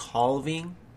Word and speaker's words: halving: And apparently there's been some major news with Bitcoin halving. And I'll give you the proper term for it, or the proper spelling. It's spelled H-A-L-V-I-N-G, halving. It sounds like having.